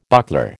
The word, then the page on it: buckler: American English